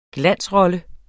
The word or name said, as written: glansrolle